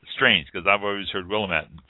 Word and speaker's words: Willamette: Not to interrupt you, Portia, but Willamette. You pronounce it. I've always heard, and I've always said Willamette, but it's Willamette.